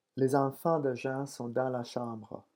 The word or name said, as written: les enfants de Jean